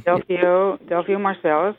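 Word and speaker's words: Delfeayo Marsalis: Delphio Marcellus, one of the famed Marcellus brothers.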